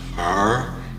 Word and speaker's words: Hfuhruhurr: You're the first object that ever pronounced it right. – How else could you pronounce it? It's H F U H R U H U R R, isn't it? Yes.